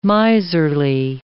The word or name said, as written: miserly